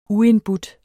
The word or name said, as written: uindbudt